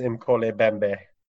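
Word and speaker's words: Mokele-mbembe: And yes, I watched a YouTube video to see if I was pronouncing it correct. It is Imkole Bembe.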